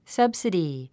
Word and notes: subsidy: sʌ́bsədi; サァブスィディ

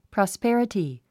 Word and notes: prosperity: prɑspérəti; プロスペラティ

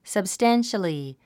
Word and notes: substantially: səbstǽnʃəli; サブスタァンシャリィ

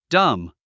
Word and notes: dumb: dʌ́m; ダァム

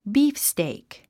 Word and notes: beefsteak: ˈbiːfsteɪk; ビーフステイク

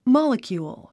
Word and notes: molecule: mάləkjùːl; モラキュール

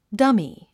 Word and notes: dummy: dʌ́mi; ダミィ

